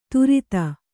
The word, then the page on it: ಧೃತಿ: Kannada